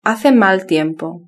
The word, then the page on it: hace mal tiempo: Spain Spanish